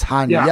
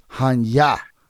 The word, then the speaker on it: Hanya: And it's Hanya, yeah. Because I keep forgetting these are Russians, not Arabic. I keep trying to pronounce that Arabic. But it's Hanya. This is Russian.